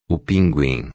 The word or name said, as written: pinguim